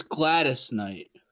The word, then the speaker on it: Gladys Knight: Gallus Knight is singing the national anthem, and she's a soul girl. Obviously, the queen of soul. – Over. Yep. Over. – It's Gladys Knight.